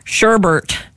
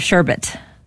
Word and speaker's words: sherbet: Rachel said she grew up saying sherbert instead of sherbet. Sorbet, sherbet, sherbert. – Sherbert. That's the way you say it. – I call it sherbet too. – That's the correct way to say it.